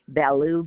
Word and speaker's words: Ballou: Two words, M-A-R-Y and then A-N-N. – Okay, and your last name? – Ballou, B as in ball, A-L-L-O-U.